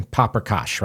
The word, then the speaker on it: paprikash: And the other one, though, I want to do is called chicken paprikash, right? That's how you say it.